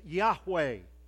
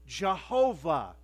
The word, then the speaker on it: Yahweh: Four Hebrew characters that we would loosely pronounce as Yahweh. Jehovah is the common way that that's said today.